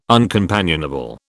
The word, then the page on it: uncompanionable: British English